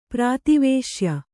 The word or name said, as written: ಪ್ರಾತಿವೇಶ್ಯ